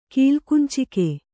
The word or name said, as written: ಕೀಲ್ಕುಂಚಿಕೆ